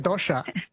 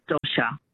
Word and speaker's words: dosai: So she said dosha. – No, they say, they spell it like D-O-S-A-I.